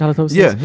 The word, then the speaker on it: halitosis: Or what about, is that how you say it, halitosis? – Yeah.